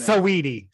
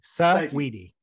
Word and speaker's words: Saweetie: Saweetie. Saweetie.